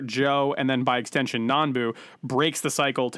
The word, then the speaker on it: Nanbu: Nabu, yep. Mr. Nabu. Let me see if I have the right name here. Mr. Nanbu, sorry.